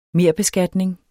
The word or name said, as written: merbeskatning